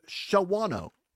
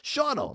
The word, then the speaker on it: Shawano: I'm going to be on the road this weekend, Zomers, going up to Shawano. That's not how you say it. Shawano.